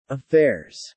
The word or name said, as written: affairs